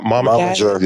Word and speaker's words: Momager: Is that how you pronounce that? Momager. – Yeah, you got it.